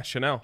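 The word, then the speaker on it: Chenal: In little words, S-H-A. – Shuh. – Dash. In all caps, N-E-L-L. – Yeah, Chanel.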